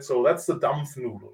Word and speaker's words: Dampfnudel: German specialty, it's called, pronunciation first, a Dampfnudel. Can you say Dampfnudel? – Dampfnudel? – Dampfnudel, perfect.